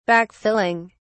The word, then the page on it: backfilling: British English